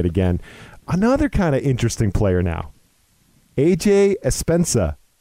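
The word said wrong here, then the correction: Epenesa: Correct me if I pronounce that wrong, because phonetics is my Achilles heel, Nick. But defensive end, second round, edge rusher, hit or miss for you. – It is Epinesa, actually.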